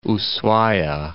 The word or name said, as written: Ushuaia